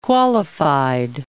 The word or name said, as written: qualified